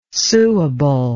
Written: suable

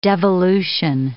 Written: devolution

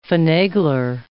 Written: finagler

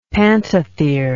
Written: pantothere

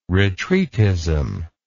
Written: retreatism

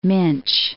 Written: Minch

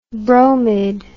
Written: bromid

